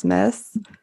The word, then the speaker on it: Myss: So really actually, so yeah, the child archetypes, Caroline Miss, I hope I'm pronouncing that right. M-Y-S-S Miss.